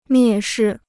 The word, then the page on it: 蔑视: miè shì